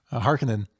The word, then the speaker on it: Harkonnen: Throwing that syllable in there. Harkonnen.